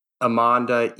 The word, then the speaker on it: Emonda: It's Amanda, E-M-O-N-D-A. Yeah, so no, but close.